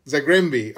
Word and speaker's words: Zagreb: And that move from, am I saying it right, Zagreb? Or Zagreb? – Zagrembi.